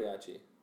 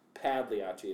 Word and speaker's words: Pagliacci: But it's not Pagliacci, it's just, Pagliacci. – Right. – Mm-hmm. Which is kind of close to Pagliacci.